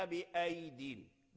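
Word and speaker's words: بأييد: هل ننطق بياء أين؟ لا، بياء واحدة ساكنة، بياء واحدة ساكنة، بأيدين. والسماء بنيناها بأيدين، بأيدين.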